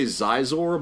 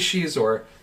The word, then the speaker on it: Xizor: And in the novel, you have a villain who's a green humanoid with a big ponytail, and the alien's name is X-I-Z-O-R. So how do you pronounce that? – Okay, I would say Zizor, but isn't it Shizor? – It is Shizor.